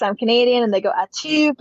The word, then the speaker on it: Atout: How do you pronounce it? – Atout. – Okay. There you go. See? Easy enough. Alicia Atout. – I know. It's so simple. Everyone's kind of scared to say Atout because they don't want to be offensive, but it's literally what it is.